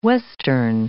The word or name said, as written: western